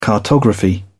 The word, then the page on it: cartography: British English